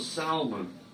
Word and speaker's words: salmon: Pulls a salmon right out of the water. That's right, salmon. Salmon has a silent L, ladies and gentlemen.